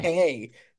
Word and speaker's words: Timothée: Timotei. – Have you heard him say that? He's like, actually, it's pronounced Timotei, but Timotei, like, nobody says.